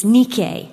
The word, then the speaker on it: Nike: It's the word Nike, or if you're going to pronounce it correctly in Greek, it's Nike.